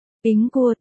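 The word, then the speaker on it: Bính Cua (苹果): Trong tiếng Trung, quả táo được phát âm là Bính Cua, đồng âm với chữ Bình trong từ đêm bình an.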